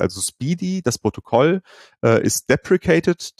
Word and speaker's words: SPDY: also SPDY, ausgesprochen Speedy, ähm, was ähm, äh, ausprobieren wollte, wie können wir denn diese Probleme in einem neuen Protokoll lösen.